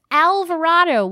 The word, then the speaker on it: Alvarado: And it turns out it was spelled with an E. I'm like, it's Alvarado with an A.